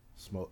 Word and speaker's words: sommelier: Dwayne, it sounds like in another life you could have been a wine connoisseur or a sommelier. – Wait, how did you say that? – Sommelier.